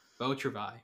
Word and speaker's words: Beau Travail: Beau Travail.